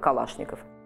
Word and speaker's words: Калашников: Соответственно, мы произносим ее как Калашников.